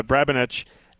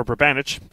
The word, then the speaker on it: Brabenec: Brabenec and or Brabenec, pardon me.